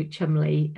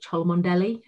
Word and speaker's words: Cholmondeley: But I lived in a really small place called Bickley, which is near a place called Chumley, which anybody who's ever been to Chumley, there's a castle there, and there's a very good pub called the Chumley Arms, and everyone calls it Cholmondeley because of the way it's spelt, so it's quite funny.